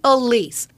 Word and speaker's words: Elise: It's Elise. Elise is not hard. If you look at it, it's very phonetically spelled. It's very easy. Elise, not hard. – Yeah, you would think that. But it's apparently very, very hard. – Yeah, because you get called Elsie.